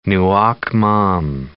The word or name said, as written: nuoc mam